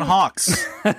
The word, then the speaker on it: Hawkes: Uh, John Hawkins. Mm. – John Hawkes.